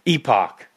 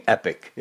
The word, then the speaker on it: epoch: And I think you've pronounced it right. How did you pronounce it again? – Epoch? – Epoch. Okay. I think that's a better way to let people know that you're not saying epic.